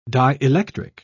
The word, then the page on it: dielectric: British English